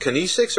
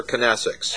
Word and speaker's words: kinesics: And Catherine Dance is an expert in, is it kinesics or kinesics? – It can be pronounced either way. I say kinesics, but that's probably my Midwest upbringing.